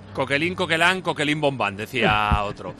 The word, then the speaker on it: Coquelin: Nos ayuda a los oyentes desde Francia, en este caso con la pronunciación, se dice Coquelin, Coquelán, porque tú, porque te. Dices. Y otro dice que no.